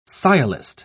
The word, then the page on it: sciolist: British English